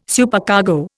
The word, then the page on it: Supercargo: Indian